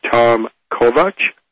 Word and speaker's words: Kovach: Kovach is spelled K-O-V-A-C-H, just like it sounds.